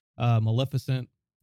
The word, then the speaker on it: Maleficent: But it's got the dragon from that one movie about the dragon, Maleficent.